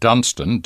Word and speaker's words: Dunstan: For listeners who are interested in finding out more about Great Western, I suggest you visit your local library and read David Dunstan, D-U-N-S-T-A-N, David Dunstan's excellent book, Better Than Pomard. Better Than Pomard. Pomard is spelled P-O-M-A-R-D.